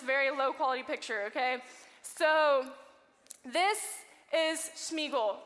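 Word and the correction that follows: Sméagol: Cutter, did I say his name right? Smeagol. Oh, thank you. This is Smeagol.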